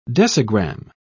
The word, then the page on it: decigram: British English